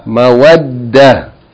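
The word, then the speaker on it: مَوَدَّةٌ: Shadda on the dal. Mawaddatun. Mawaddatun. If we stop on this word, how will we pronounce it? Mawadda. Mawadda. Naam.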